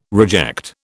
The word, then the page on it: reject: British English